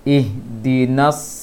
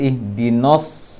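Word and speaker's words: Ihdinas (اهدنا): Karena itu pengucapan sad tidak boleh monyong bibirnya. Kalau monyong bibirnya akan terbaca no huruf nafathahnya. Ihdinas, jangan ihdinos, ihdinas sirata.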